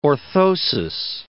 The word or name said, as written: orthosis